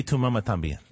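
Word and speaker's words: Y Tu Mamá También: Etu Mama NBN? – Etu Mama Tambien.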